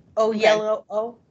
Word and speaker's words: Oyelowo: I didn't realize that was David Oyelowo. Oh, I can never pronounce his last name. – I think you said it right. Oyelowo.